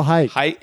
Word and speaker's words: Haik: The other roommate was this guy named Hike Kocharian. – Oh. – Who... Don't laugh at I'm Armenian. Don't laugh at an Armenian name. – Is that Armenian? – That's Armenian. – How do you spell Hike? – H-A-I-K. – Is there ever a variant where it's spelled H-A-Y-K?